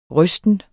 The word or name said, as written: rysten